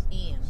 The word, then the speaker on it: Ian: You're not traumatized by Ina? I wonder, or Ina, what was the hurricane's name? – Oh. – Ian. – That's Ian.